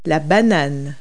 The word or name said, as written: banana